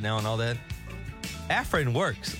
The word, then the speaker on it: Afrin: Tige's got some Afrin nasal spray. Is that how you say it, Tige? – Yeah, here's the thing with Afrin.